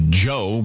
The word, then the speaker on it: DJO: We're keeping things going this time around with an artist who calls himself Joe, but it's spelled D-J-O, and that's the music project of actor Joe Keery.